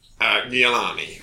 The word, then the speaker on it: Giuliani: That's how I pronounce his surname. – How do you pronounce his surname? – Giuliani.